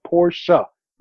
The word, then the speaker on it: Porsche: The man's name is Porsche, for crying out loud already. It has two syllables in it, Porsche, right?